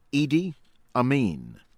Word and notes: Idi Amin: American English; from Uganda

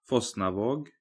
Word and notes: Fosnavaag: Norwegian

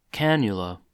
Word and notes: cannula: American English

